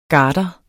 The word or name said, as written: garder